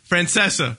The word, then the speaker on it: Francesa: We're talking about Mike Francesa in New York. How do you say his last name exactly? – Francesa.